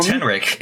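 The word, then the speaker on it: Tenrec: is it pronounced Tenric? It's like hedgehog thing. – Oh yeah, Tenric.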